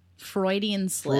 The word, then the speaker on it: Freudian: Fruitian Slips? – Freudian Slip. – Freudian Slip. Look at this. – Oh, Lord. – I just did it, right? I fucked it up. – That's not a Freudian slip, though.